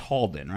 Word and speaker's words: Halden: Felt like you said it wrong, but looks H-A-L-D-E-N. That's Holden, right? – Yeah, well, I mean, because I've heard of the name Holden before.